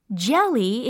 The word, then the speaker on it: jelly: 발음이 너무 귀엽죠? jelly, jelly, jelly. 그죠? 젤리 아니에요. 젤리 아니에요. jelly라고 발음해 주십시오.